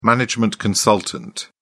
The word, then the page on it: management consultant: British English